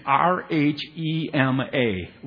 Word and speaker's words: rhema: And if you were to spell it and uh, transliterating it from the Greek, it's R H E M A, rhema.